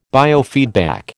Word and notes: biofeedback: American English

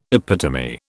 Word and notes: epitome: British English

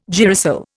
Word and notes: girasol: Indian

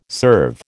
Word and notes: serve: American English

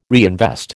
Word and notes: reinvest: American English

